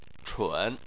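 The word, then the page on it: chun: tone 3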